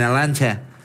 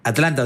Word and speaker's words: Atlanta: Me vine hasta acá, hoy feriado, estaba hace 10 minutos, estaba en Arlancha. – ¿Dudaste en volver? – En Arlancha. No la pronuncia en la T, ¿viste? – Pasa que hay muy cerros, muy yanqui. – Es sí. Atlanta, donde Argentina ganó, es la casa.